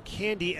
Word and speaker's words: Cande: So this is the 5th year for Candy as a starter, as a pro. His last name, by the way, is spelled C-A-N-D-E, pronounced like cotton candy.